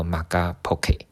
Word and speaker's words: Magapoke: 诶、欸，用英文拼拼成用英文来拼的话是 M A G A P O K E，就念作 Magapocket。